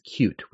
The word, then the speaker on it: Qt: But you're right that that was a model that was made available under Qt, at least at one point. Or I guess it's actually technically pronounced cute, which is really strange.